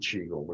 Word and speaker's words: Qigong: Uh, so that's the pronunciation, Qigong, but it's a different one. It's got to spell QI, which is pinyin Chinese, eh, but yeah.